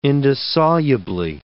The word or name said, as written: indissolubly